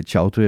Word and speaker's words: Chaudhry: Øhm, jeg ved blandt andet, at han hedder øh, Chaudhry, at det udtales sådan,